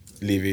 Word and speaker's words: Levy: How do you say Levi? Levy. Daniel. – Levy, yeah, that's it.